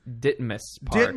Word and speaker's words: Ditmas Park: So this, to open up a little bit about the DeMoss? – Sorry, I mispronounce it every single time. – Please. – Ditmas Park. – Ditmas Park. Oh, there we go.